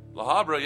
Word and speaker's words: La Habra: They're from La Habra, California. Am I saying that right? – La Habra, yeah.